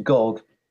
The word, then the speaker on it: Gog: Oh, Gog rhymes with dog.